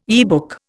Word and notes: yearbook: Indian

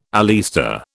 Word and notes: oleaster: British English

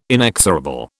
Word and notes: inexorable: British English

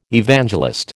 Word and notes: Evangelist: American English

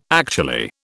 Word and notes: actually: British English

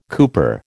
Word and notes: cooper: American English

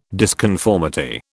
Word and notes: disconformity: British English

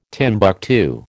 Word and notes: Timbuctoo: American English

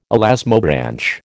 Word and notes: elasmobranch: American English

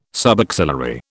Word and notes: subaxillary: British English

